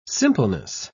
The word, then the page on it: simpleness: British English